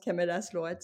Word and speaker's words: Camellas Lloret: Moi, je le prononce Camelas Lorette, c'est parce que j'ai répété euh, ce que, ce que Annie m'a dit.